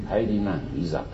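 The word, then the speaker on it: 帰去来: で、あの漢字で書くとですね、帰郷来、帰るっていう帰、それから去る、そして来る、帰郷来と書いて帰りなんいざと読むんだそうですね。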